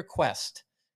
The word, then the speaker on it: Kuest: There was an amazing performance by a young man from BYU named Peter Quest. Quest, not spelled like Johnny Quest, spelled K-U-E-S-T.